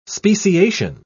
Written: speciation

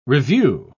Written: review

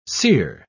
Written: sere

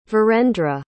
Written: virendra

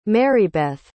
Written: marybeth

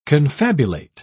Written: confabulate

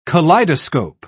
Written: kaleidoscope

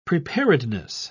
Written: preparedness

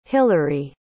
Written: hillary

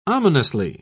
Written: ominously

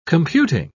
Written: computing

